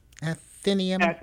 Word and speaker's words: Athenaeum: Athenaeum. – Athenaeum.